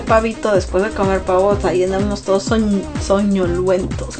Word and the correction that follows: somnolientos: ¿Cómo se dice? Somnolientos, perdón. Estoy como el Chavo del Ocho, ¿no?